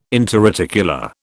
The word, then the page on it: interarticular: British English